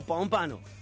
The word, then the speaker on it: Pompano: Pompano, se dice Pompano.